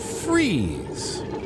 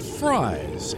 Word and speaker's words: Fries: McCall is pronounced Freeze. That's Freeze, not Fries, as in French fries.